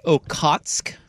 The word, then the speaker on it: Okhotsk: Is pronounced Okhotsk.